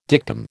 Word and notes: dictum: American English

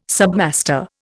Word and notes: submaster: Indian

